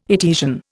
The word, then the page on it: Etesian: Indian